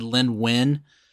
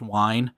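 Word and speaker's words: Wein: So I think I've said his name on this show before, but I think I said Lynn Wynn. – Okay. – And I think more of my research has discovered it's pronounced Wine.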